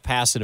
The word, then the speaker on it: Passan: Passan.